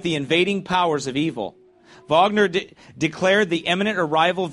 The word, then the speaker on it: Wagner: Wagner? – Yeah, it says Wagner for some reason. Okay. Oh, that's how they say it? – Yeah. – Oh, they say it with a V. Sorry. Okay. Wagner.